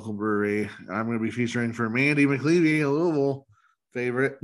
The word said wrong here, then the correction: McKelvey: Mandy McKelvey, you mean? – Yeah, whatever. Is it McKelvey? – It's McKelvey. – Oh, shit. I keep on saying her last name wrong. Oh, well. – It happens. I mean, I don't know if she cares, but yeah. Just McKelvey. Mandy McKelvey.